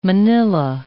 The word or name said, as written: manilla